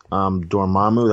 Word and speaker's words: Dormammu: I mean, we sort of knew throughout the whole entire time that Cassilius was working for, Dormammu. That's how you pronounce his name.